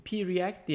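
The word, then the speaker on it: Preact: Tanesi de pre-React diye mi okunuyor? Biraz daha... – Pre-React. Yani biz onu kullanıyoruz bu arada. Pre-React kullanıyoruz.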